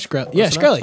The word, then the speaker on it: Shkreli: Anyways, did you see the Martin Shkreli news? – Nope. – No. – Did I say that right? – Yeah. – Close enough? – Shkreli.